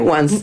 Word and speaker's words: Once: Se escribe Once, como en inglés Once. – Como Once Upon a Time.